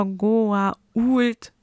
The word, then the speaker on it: Goa'uld: Und Beau Bridges zum Beispiel, der Schauspieler, der General Landry spielt, beharrte später in Staffel 9 und 10 darauf, wirklich die Aussprache Goa'uld zu sagen, weil er halt einen Freund hat, einen Schauspielerfreund, Elliot Gould.